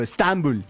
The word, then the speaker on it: Estambul: Nosotros le ponemos el acento en otro lado. Estambul. Así le decimos nosotros, los más cercanos a esa tierra.